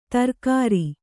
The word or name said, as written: ಧಾರಾಗ್ರ